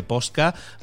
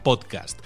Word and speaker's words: podcast: le llaman desde posca hasta podcast, de todo, se llama podcast.